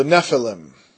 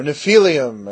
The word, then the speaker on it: Nephilim: Now the word giants is the Nephilim, or Nephilim, as my old teacher used to say, or Nephilim, actually, would probably be the proper pronunciation. Nephilim, not Nephilim.